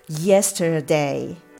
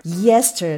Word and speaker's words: yesterday: 頭がアクセントが頭にアクセントがあるので yesterday yesterday ですね ye, ye ここが一番強い音になりますはい、